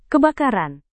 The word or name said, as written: kebakaran